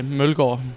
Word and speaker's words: Mølgaard: You're pronouncing it in a different way in Denmark. Okay. So, a little bit, just like Bjorkstrand. So, how would you pronounce it? – Well, in pure Danish, I would say Mølgaard.